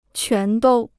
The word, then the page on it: 全都: quán dōu